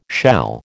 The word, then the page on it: shall: American English